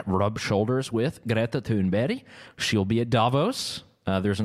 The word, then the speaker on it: Greta Thunberg: So Greta Thunberg, that's the correct Swedish pronunciation, she'll be there.